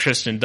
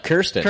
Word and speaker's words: Kirsten: In fact, I didn't like Kristen Dunst before this. – Yeah. You couldn't even pronounce her name right. Her name's Kirsten. – Kirsten. – And you called her Kristen.